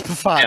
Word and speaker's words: Pfaadt: You don't pronounce the P. It's Pfaadt.